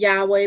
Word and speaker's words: Yahweh: Okay, we pronounce it Yahweh, but, you know, again, people argue how to pronounce it.